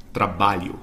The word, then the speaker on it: trabalho: The next one on the list, this one's kind of impossible to pronounce for non Brazilians or non Portuguese speakers, but it's the LH, the LH or LH. So, for example, the word um, trabajo is pronounced trabalho. It's trabalho in Portuguese.